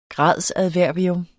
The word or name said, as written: gradsadverbium